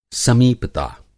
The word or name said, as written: समीपता